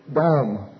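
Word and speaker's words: Dam (דם): But in the word in the Hebrew in the Old Testament, in the Hebrew, is the word dam. D-A-W-M is the pronunciation of it. The spelling of it is D-A-M. D-A-W-M is the pronunciation of it. Dam.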